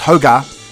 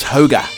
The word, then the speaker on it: hygge: Hygge, pronounced hoga, roughly means cozy contentment, something that brings out warm, fuzzy feelings.